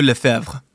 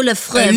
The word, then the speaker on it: Lefebvre: Chef Ludo Lefebvre. – Chef Ludo Lefebvre.